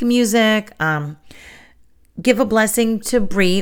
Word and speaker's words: Brigid: And I say Bri, but it's spelled B-R-I-G-I-D. So a lot of people pronounce it Brigid. So I don't want to confuse you, but I do call her Bri. That is the pronunciation that I've always known.